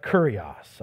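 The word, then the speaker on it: kurios (κύριος): You know, this word that gets translated Lord is the word just pronounce it like curious. It's like kurios, all right?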